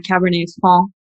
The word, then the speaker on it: Cabernet Franc: So I think the one that sparked the most controversy was when I posted about on Cabernet Franc Day, and the C is not pronounced, and people were ballistic.